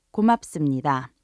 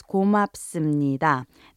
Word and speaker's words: 고맙습니다: Once again, it's pronounced 고맙습니다. 고맙습니다. But on the clip, we hear Dong-hyuk first saying 아무튼 before he says 고맙습니다.